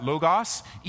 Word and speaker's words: logos: So circle this word and write next to it somewhere, logos, L-O-G-O-S. Some people pronounce that logos. Either way, that's the word, logos.